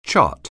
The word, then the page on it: chart: anglais